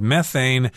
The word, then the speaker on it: methane: I should mention that the word methane can also be pronounced methane. I think I've heard uh, people from the UK say methane but Americans say methane. It depends on where you are.